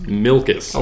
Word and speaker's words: Milkis: Spelled like it sounds? – M-I-L-K-I-S. Milkus. – I would have done U-S, but okay.